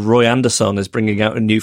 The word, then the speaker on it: Roy Andersson: Roy Andersson.